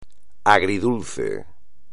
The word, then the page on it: agridulce: espagnol